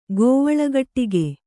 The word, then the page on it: ಗೋವಲೆಗಟ್ಟಿಗೆ: gōvalegaṭṭige